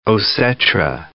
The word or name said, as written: osetra